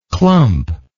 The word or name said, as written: clumplike